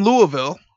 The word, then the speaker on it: Louisville: We do say Louisville because we know people. My brother used to be there, so I know you have to say it like Big Pun says Little Italy. Louisville. – Yeah. Do not say Louisville. Do not say Louisville. It is 100% Louisville.